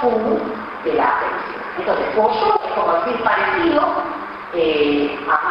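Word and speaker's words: Fuo Shou (佛手): Se dice Fuo Shou, Fuo, no puse mano, así sí, Shou. Ustedes conocen el Shou, ¿no es cierto? Del Shou Yi, ¿se acuerdan que vemos el lugar? De Shou de mano y de máquina, Shou Yi. Entonces, Shou, tercer tono, mano, se pronuncia igual o parecido a Shou, que es la realidad.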